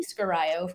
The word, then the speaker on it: Scurio: I honestly don't know how my last name is pronounced, because I'm the only person in my family with it. So it could be Scario for all I know.